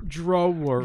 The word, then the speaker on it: drawer: We go, check the top drawer. – Drawer. – Drawer, because you just want to say draw.